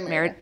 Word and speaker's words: Merida: Yeah, Merida.